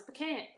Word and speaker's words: pecan: But listen, it's P-E, right? There's no two E's in the word. – I know. – It's not a long E sound. It's pecan.